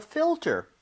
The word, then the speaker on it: filter: F-I-L-T-E-R. 자, 근데 발음 주의하셔야 되는 게 지금 fill 할때 L 있죠? – 네. – 그리고 ter 할때 R. Right? – Wow. – L-R. 이 순서로 되어 있기 때문에 네. – 혀하고 입 모양이 제대로 들어가야 돼요.